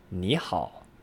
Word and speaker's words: 你好: Remember, the double third tone exception is in play here. It's pronounced 你好, as if it were that second tone, and then the third tone.